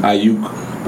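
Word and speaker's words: Aiyuk: That's, Ayuk.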